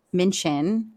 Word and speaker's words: Minchin: The American actress, 46, spoke to Louise Minchin. Am I saying that correctly? Minchin? – Yeah. Yeah.